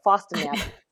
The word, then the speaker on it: Fostamatinib: I always want to put an N there. So Fostamatinib.